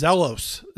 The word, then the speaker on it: zelos: Uh, and then he talks about jealousy, you know, the, this envy, the, the, the Greek word is zelos, Z E L O S, uh, is how we would pronounce it.